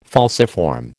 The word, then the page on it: falciform: American English